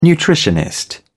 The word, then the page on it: nutritionist: British English